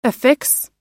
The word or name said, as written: affix